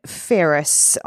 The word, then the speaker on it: Ferris: And that code is my last name, Ferris.